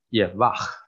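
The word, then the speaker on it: Yevah (יהוה): 因为这个希伯来文第一个字是 Yod， Hey， Vav， Hey，啊，Yevah，啊，这是希伯来文发音。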